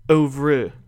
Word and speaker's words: oeuvre: Oeuvre, which makes more sense.